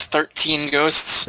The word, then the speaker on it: Thir13en Ghosts: Yes, it is THIR13EN ghosts. 13 ghosts. – That's pronounced 13 ghosts. – That is pronounced.